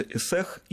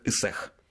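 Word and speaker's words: Ысыах: Этот праздник отмечается в день летнего солнцестояния, он называется Эсех, и вот, кстати, потому как человек произносит это слово, становится понятно, был ли он когда-нибудь в Якутске или читал только об этом. Потому что в русской транскрипции название праздника пишется как «Исыах», угу, а произносится он как «Исех».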